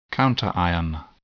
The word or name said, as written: counterion